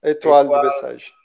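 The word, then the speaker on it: Étoile de Bessèges: Etoile de Bessèges. Etoile de Bessèges, a pronúncia certa, a estrela de Bessèges.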